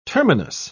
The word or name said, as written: terminus